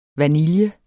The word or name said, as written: vanilje